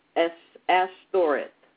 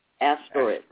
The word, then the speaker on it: Ashtoreth: And Shelemoth went after Ashtaroth. What? How do you pronounce that? – Where is it? – 11:5. – Oh, and Shlomo went after Ashtoreth. Ashtoreth.